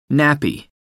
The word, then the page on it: nappy: American English